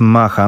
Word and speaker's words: maja: Skoro Maja to jest Macha, no to tak idąc dalej w wymowę hiszpańską, to teraz Gorillaz na antenie Trójki, być może Clint Eastwood z I-23 po 11.00.